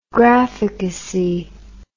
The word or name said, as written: graphicacy